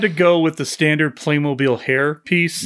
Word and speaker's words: Playmobil: Playmobil.